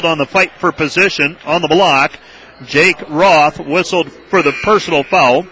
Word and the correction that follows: Rothy: I beg your pardon, Rothy, that is. Have to look at my pronunciations a little closer.